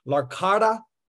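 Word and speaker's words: L'Arcada: Again, we're with Coach Dave Bintz, uh, currently serving as the sports director at Larcada.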